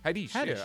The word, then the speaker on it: Haddish: Is it Haddish? – Haddish.